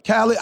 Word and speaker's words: Khaled: I always say his name wrong. Is it DJ Khaled? – Yeah. – I saw his thing the other day. DJ Khaled? – Khaled.